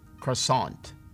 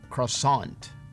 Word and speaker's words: croissant: Y esta, vea cómo se escribe. Y se dice croissant. Croissant. Croissant.